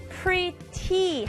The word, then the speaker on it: pretty: Pretty 하고 발음할 수도 있지만 이 T의 T 소리가 중간에 있을 때는 약하게 발음할 수 있어요.